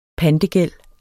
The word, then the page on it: pantegæld: dansk